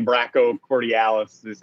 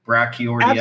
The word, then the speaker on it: brachioradialis: I was thinking about saying brachiocordialis. – Brachiocordialis? – That's what it purports to be. – Should we look it up on the pronunciation thing? Brachioradialis. – Absolutely not. I'm just going to keep saying brachioradialis.